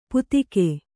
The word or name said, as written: ಪುತ್ತಿಕೆ